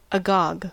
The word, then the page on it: agog: American English